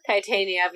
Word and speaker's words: Titania: Titania, because we definitely messed that up the first, like, three episodes, or two episodes.